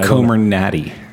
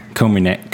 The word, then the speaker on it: Comirnaty: The new stuff, the Cominitary, what the hell do they make that name up for? I don't even know how to say it. I look at it. – Comirnaty. Comirnaty.